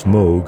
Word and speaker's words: Moog: And remember, it's Moog rhymes with Vogue.